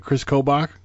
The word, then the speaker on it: Kris Kobach: I'm pronouncing it right. Kris Kobach? – Yes, that's correct.